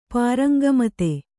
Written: ಪಾರಂಗಮತೆ

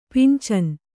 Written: ಪಿಂಚನಿ

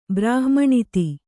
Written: ಬ್ರಾಹ್ಮಣಿತಿ

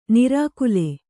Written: ನಾರಿಕೇಲಿ

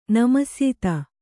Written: ನಮಸ್ಯಿತ

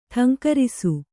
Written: ಠೇಂಕರಿಸು

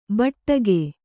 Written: ಭಾಡಿಗೆ

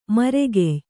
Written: ಮರಗಯ್

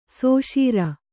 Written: ಸುಷಿರ

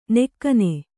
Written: ನಗಣ

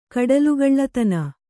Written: ಕಡಲುಗಳ್ಳತನ

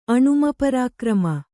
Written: ಅಣುಮಪರಾಕ್ರಮ

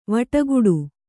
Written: ವಟಗುಟ್ಟು

